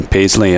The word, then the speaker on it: Paisley: The company started in Paisley, which is where I was born and bred, and I still sit in a mill in Paisley and do my day job. Yeah, is that Scotland? – It's in Scotland. – Okay, did I say it right? – You did.